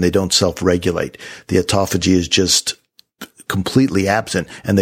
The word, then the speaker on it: autophagy: it downregulates the autog, autophagy, autophagy. – Autophagy. There we go.